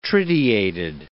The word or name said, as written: tritiated